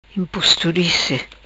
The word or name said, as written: Imposturice